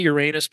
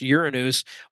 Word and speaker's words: Uranus: Uranus, they say Uranus, but it's pronounced Uranus, also goes into Taurus later in the month of May.